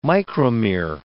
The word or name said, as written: micromere